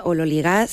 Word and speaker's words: ololigás: Teóricamente se pronuncia ololigás según la, la transcripción fonética del griego al español,